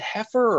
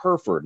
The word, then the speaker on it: Hereford: So I would call them and I'd be like, hey, listen, it's spelled H E R E F O R D. Is it heifer or Hereford? And they would laugh and they'd be like, well, it could be a heifer, but that that that's actually pronounced Hereford.